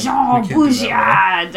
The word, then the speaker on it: Jean Baudrillard: Jean Baudrillard. I'm hoping I'm pronouncing that anywhere near it's correct. Pronounced more Frenchly than that, but we can't. – Jean Baudrillard.